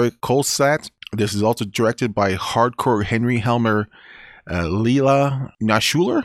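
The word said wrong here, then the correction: Ilya Naishuller: Is that how you say it, Rich? – Ilya Nashuler.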